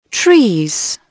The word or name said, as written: trees